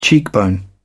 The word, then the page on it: cheekbone: British English